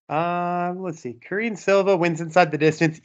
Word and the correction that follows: Karine: ITD Karini, I guess actually is how I should say her name. Karini Silva. ITD +100.